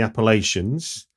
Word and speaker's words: Appalachia: Right, then moving into the 70s, and we're going to be following a wandering ballad singer in the Appalachians for The Legend of Hillbilly John. – Yes, although it's actually pronounced Appalachia. Even though everybody in my movie pronounces it wrong.